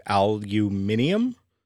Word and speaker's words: aluminium: but they make mention of the word aluminum, which in London, and it was very well pronounced, they said aluminium. It's the only place I've ever heard that before. And I'm like, they must be British.